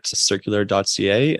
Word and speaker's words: Circulr: Yeah, so you can find me at circulr.ca. And the key point here is that it's spelled C-I-R-C-U-L-R. So we were talking about it earlier. It's Circulr without the A.ca,